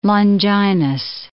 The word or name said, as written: Longinus